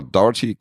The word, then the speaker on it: Dougherty: Michael Dougherty, oder wie wird der Herr ausgesprochen? – Ja, Dougherty oder Dougherty vielleicht. – Mhm. – Aber Dougherty klingt richtig.